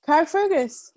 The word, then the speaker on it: Carrickfergus: Is it Carrickfergus? – Carrickfergus.